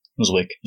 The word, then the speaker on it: Zwick: It was directed by, do you say his name, Edwards? Is it just Zwick? Zwick? – Zwick. – Yeah. Zwick? – Zwick. – Zwick, okay.